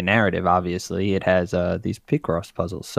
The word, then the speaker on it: Picross: Most people say Picross, but Right.